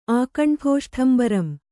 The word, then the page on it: ಆಕಂಠೋಷ್ಠಂಬರಂ: kannada